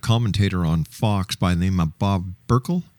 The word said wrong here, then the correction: Beckel: Beckel. I mispronounced it. It was okay. Beckel.